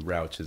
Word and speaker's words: Rauch: Rauch is how you pronounce it.